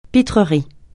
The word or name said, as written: pitrerie